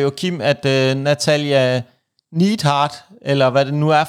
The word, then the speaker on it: Neidhart: Jim the Anvil Neidhart. Ja. Nå, det er sådan, det udtales.